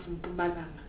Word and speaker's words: Mpumalanga: I want you to help Mr. Martin Bayse, how, as a Zulu man yourself, how to pronounce Mpumalanga.